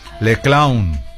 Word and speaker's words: Les Clowns: Es que en francés la S al final no se pronuncia. Les Clowns.